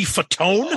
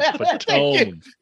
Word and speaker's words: Fatone: See, I used to laugh at that because if you look at the spelling of his last name is spelled Fat One. – Yeah, yeah. – And I'm like, I'm like, your name ain't Fatone. Your name's Joey Fat One.